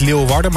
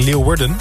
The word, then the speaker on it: Leeuwarden: Kreeg wel net een berichtje van Wesley van Leeuwarden. Niet Leeuwarden, maar Leeuwarden. Leeuwarden, denk ik. Niet dat de klemtoon op Wur ligt.